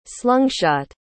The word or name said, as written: slungshot